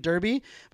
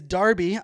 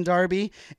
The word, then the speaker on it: Derby: I was pronouncing it Derby, but I was corrected earlier this morning. It is Derby. I'll be in Derby.